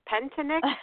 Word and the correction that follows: Pentatonix: It's actually pronounced pentatonix. – Okay. – Pentatonix, but I think it's kind of like the first T is silent, so it's pentatonix, or at least that's how I've heard it pronounced.